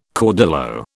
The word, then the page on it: caudillo: British English